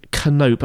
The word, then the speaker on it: Canope: Okay, well, let's move on to that interview then with Dr. Steve Canope. I've got the surname right there, haven't I, Jeff? You pronounce the K, don't you, Canope? – Yes, you do.